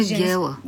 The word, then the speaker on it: Гела: А в този район е характерно преместването на ударението и има и други такива местни имена, които са с ударение на последната сричка. Така че... – Това е женското име Гела.